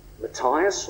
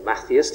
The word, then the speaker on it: Matthias: If we'd have read the early bit of Acts, they choose a new disciple to replace Judas, Matthias or Matthias, depending on your pronunciation.